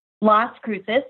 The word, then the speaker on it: Las Cruces: And it's La Cruces? Is that how you say it? – Las Cruces, yes.